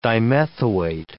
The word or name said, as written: dimethoate